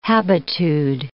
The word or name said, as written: habitude